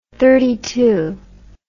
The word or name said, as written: thirty twelve